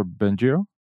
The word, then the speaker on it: Bengio: And even some of the like biggest players in the field, like, I don't know how you say his name, Yasha Benigna. How do you say that guy's name? – Yasha Bengio.